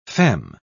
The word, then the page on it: feme: British English